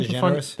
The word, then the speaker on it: DeGeneres: Well, how'd you say her name? – DeGeneres.